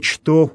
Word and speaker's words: что: Далее, «что уподобилося еси первой Еве». Обратим внимание, что местоимение «что» в церковнославянском языке читается так, как оно пишется, то есть именно буква «ч» произносится на первом месте.